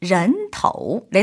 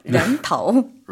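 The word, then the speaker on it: 렌터우 (人头): 인두 측명에서 사람 머리, 인두의 중국어 발음 정답은 2번이었죠. 정답 2번, 렌터우. 렌탈이 아니었죠? 렌터우.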